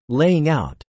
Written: laying out

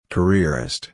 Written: careerist

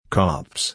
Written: cops

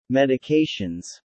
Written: medications

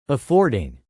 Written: affording

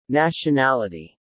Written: nationality

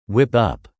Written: whip up

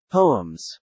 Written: poems

